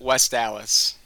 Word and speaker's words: West Allis: It's a Wisconsin city, so of course it doesn't make sense, but West Allis.